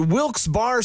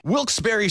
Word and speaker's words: Wilkes-Barre: And you're listening to Wilkes Barre Scranton's 97.9X. – Now, I had heard that it was supposed to be pronounced Wilkes-Barre. – Wilkes-Barre Scranton's 97.9X. The only way to pronounce Wilkes-Barre.